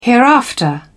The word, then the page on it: hereafter: British English